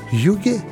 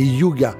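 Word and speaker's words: hygge: ça se prononce Yuge, mais en français, c'est Yuga.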